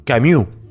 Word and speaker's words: Camus: No, no, no, es Camus. ¿No es Camus? Para mí se pronuncia Camus, la S no, no va.